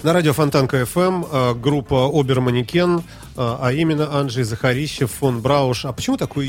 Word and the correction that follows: Захарищев: Ну, во-первых, тут немножко ударение исправить, тогда будет понятнее. – Захарищев. Захарищев, да. Простите, пожалуйста.